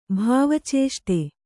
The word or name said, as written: ಭಾವಚೇಷ್ಟೆ